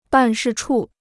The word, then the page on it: 办事处: bàn shì chù